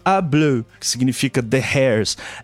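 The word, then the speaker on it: Y Blew: É, quando eu falo A, é sempre o artigo, né? O A ou O, que a gente pronuncia A, mas é o Y. Então é muito comum nomes de banda galesa começarem com Y. É, na verdade, fala A Bleu, é, os cabelos nesse caso tal.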